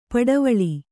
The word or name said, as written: ಪಡವಳಿ